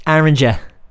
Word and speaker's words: Oranger: What's the name of that band? Oranger. It's a band called Oranger. Orange. I never knew what the band name was about, but I guess that's what rhymes with orange.